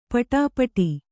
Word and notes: ಪಡಿಪಾಟ: paḍi pāṭa

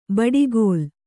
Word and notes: ಬಡಿಗೋಲ್: baḍi gōl

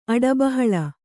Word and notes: ಅಡಬಹಳ: Kannada

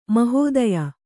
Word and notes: ಮಹೋದಯೆ: mahōdaye

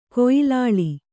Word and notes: ಕೊಯ್ಲಾಳಿ: koylāḷi

